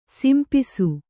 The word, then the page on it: ಸೊಂಪೆಸೆ: kannada